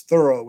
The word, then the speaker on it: Thoreau: We've mispronounced his name the whole show. It's Thoreau, which I can't quite bring my Thoreau.